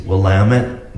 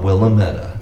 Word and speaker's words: Willamette: I told them just it's Willamette, not Willametta.